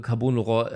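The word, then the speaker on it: Carbone Lorraine: Oh ja, CL Brakes, äh, Carbon Laurent äh, heißt es ausgesprochen.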